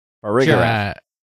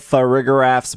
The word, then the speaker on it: Farigiraf: I would pronounce that for Farigiraph. Like giraffe for rig, for rig giraffe. – Yeah. – Get a little more J in there. – With the J sound.